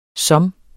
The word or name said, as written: som